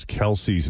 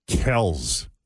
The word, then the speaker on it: Kelce: It's not Kelce, like you heard me just say. It's Kels. – Kels. – With an S sound at the end, not a Z.